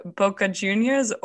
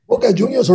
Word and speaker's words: Boca Juniors: Now, keeping up with sports, I have to ask, are you a Boca Juniors or River Plate? Now, I hope I also pronounce that correctly. – Boca Juniors or River Plate, you pronounce it perfectly.